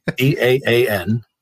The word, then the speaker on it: EAAN: Because one of the objections to the EAAN, that's how you say it, by the way, with the initials, EAAN.